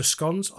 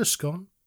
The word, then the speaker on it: scone: Well, the thing is, I said to him, I said, just talking to scones, I said, well, today we had a scone or a scone, depends how you pronounce it.